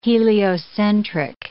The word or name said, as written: heliocentric